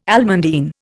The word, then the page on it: almandine: Indian